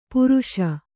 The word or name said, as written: ಪರ್ಷ